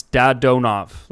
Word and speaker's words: Dadonov: So the way Russian pronunciation works is every syllable, like there's no change depending on the configuration. So it's Dadonov. Like, there's no change depending on, like, the sequence.